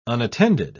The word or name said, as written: unattended